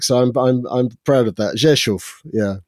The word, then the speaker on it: Rzeszów: I've been to Rzeszów, as it's pronounced.